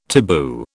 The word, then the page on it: taboo: British English